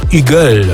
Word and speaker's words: Hugel: To będzie francuski DJ i producent Eagle. Takiego pseudonim się wymawia. Pisze się normalnie Hugel przez W otwarte.